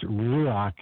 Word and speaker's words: ruach: Some people call it rock. It's ruach.